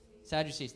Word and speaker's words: Sadducees: And what happened then is okay, The Sadducees, how do you say it? Sadducees, thank you.